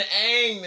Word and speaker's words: Aang: How you gonna pronounce Oba? It's been Aang this whole time. – Right. – It been a long A. You gonna turn into a... It's two A's.